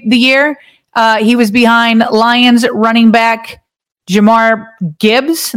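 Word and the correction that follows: Jahmyr: Am I saying his name right, Bobby? – I think it's Jameer. – Jameer.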